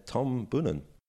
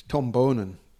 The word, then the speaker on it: Tom Boonen: Tom Boonen. – Tom Boonen. – It's Tom Boonen. You should know that by now.